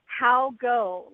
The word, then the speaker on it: Haugo: It's Haugo. Like, how goes it? – Haugo.